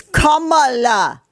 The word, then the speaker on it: Kamala: She didn't even say, Big Mike didn't even say her name right. It's Kamala.